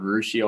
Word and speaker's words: Russia: I'm originally from a small town called Rushi, Ohio. It's spelled Russia, about 45 minutes north of Dayton.